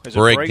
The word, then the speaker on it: Borrego: How do you say his name? Borrego? – Borrego. James Borrego, yep.